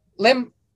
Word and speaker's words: Liam: Now, Lim, am I saying your name right? – Yeah, that's right. – Lim. – It's spelt Liam, but you could say Lim. I say Lim either way. – Okay, Lim.